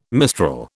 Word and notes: mistral: British English

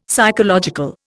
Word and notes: psychological: Indian

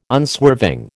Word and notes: unswerving: American English

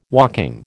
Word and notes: walking: American English

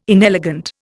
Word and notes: inelegant: Indian

